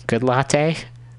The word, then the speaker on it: Goodlatte: Goodlatte? It's spelled like good latte.